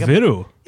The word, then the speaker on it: Viru: Because I is E, right? That's how it's pronounced. – Yeah, yeah. – So I'm saying Viru because I'm reading the I as an E.